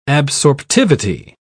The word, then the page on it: absorptivity: British English